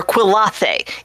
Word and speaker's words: Quillathe: It's spelled Q-U-I-L-L-A-T-H-E. No one can actually remember how to say it. Just call her Quill. No one pronounces it right. They all go Quillathe or Quillathe. It's Quillathe. Quill. Just go with Quill.